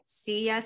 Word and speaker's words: Seiya: I want to make sure I pronounce his name right. Seiya Suzuki. Seiya. S-E-I-Y-A. – I think it's Seiya.